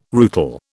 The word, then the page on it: rootle: British English